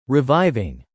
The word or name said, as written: reviving